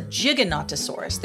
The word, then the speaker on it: Giganotosaurus: I keep saying it wrong, and I'm sorry. It's a Giganotosaurus.